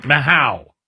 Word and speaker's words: Mahou: Mahou. – Mahou. – Mahou. – M-A-H-O-U from Cinco Estrella.